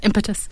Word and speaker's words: impetus: So attitudes about people who are blind are revealed in a new study that forms the impetus... Am I saying that right? – Yeah, impetus.